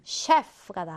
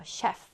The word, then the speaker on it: chef: 그 다음은 chef가 나와요. chef. 자, 발음 해볼까? chef. 자, 지금 발음 CH인데, 이 발음 기호가 이제 슈 발음을 슈. 셰프라고 발음돼요.